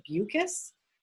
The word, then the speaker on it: Beukes: The next one I want to talk about is a book by Lauren Bukes, which is spelled B-E-U-K-E-S. On her website, she says rhymes with mucus.